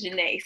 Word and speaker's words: Janae: Like, I literally just made a post where I was telling people that my middle name is Janae, not Gina, not Jenna. It is Janae.